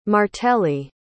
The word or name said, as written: martelli